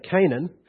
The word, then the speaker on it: Canaan: After Abram had lived 10 years in the land of Canaan, or as the Americans say, Canaan, Abram's wife Sarai took Hagar the Egyptian, her maid, and gave her to her husband Abram as his wife.